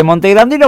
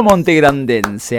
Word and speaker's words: montegrandense: ¿Montegrandino o montegrandense? Acá un banfileño que no sabe bien, ¿cómo se dice? Nadie sabe cómo se dice. ¿Cómo se dice, Julián? – Montegrandense. – Montegrandense. No sé por qué me suena montegrandino mejor.